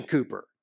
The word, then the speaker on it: Cowper: My middle child, Cooper, is named after the 18th century British hymn writer William Cooper, spelled C-O-W-P-E-R, but pronounced Cooper.